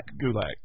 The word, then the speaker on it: Gulak: Did I say that right? – Gulak, yes.